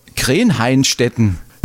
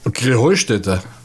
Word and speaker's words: Krähenheustätte: Ja, ihr saget immer Krähenhainstetten. Und dabei heißt es doch Krähenheustätte, oder wie es? – Krähenheustätte. – Ja, genau, und das ist die Betonung auf dem Heu.